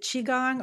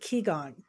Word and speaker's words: Qigong: Is it correct to say, is it Qigong or is it Qigong? – It's both. It depends on which country. – Okay. So either. So I wasn't necessarily saying it wrong. Either one is acceptable.